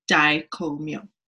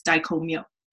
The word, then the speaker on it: Dai Ko Myo: The Master Symbol is called Dai Ko Myo, and it's pronounced Dai Ko Myo. Really fast, Dai Ko Myo. Okay. When I was taking my Reiki Master Teacher training, I had a classmate who spent a number of years living in Japan, and she was fluent in Japanese. And so I asked her, how is this actually pronounced? And she said, it's Dai Ko Myo.